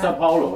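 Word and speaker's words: São Paulo: Dude, I gotta tell you, like that one of the guitarists that came out with that, how do you say it, San Powell, where were you guys? The balls to the wall video that you sent me. Where was that? – Sao Paulo, in Brazil.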